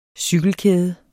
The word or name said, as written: cykelkæde